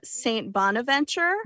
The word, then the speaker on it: St. Bonaventure: But they're matching up against St. Bonaventure. Mm-hmm. How do I say that? Am I saying that correct? – Yeah, that's it. You got it.